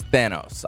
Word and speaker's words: Thanos: The correct pronunciation is Thanos, I believe.